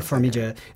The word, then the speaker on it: Farmiga: did I say her name correctly? – Farmiga.